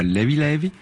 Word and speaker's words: Levé Levé: Alors volume 2, donc de Levé Levé, qui se prononce là-bas Levi Levi, ça veut dire léger léger, ou tranquillou un peu, il n'y a pas de stress.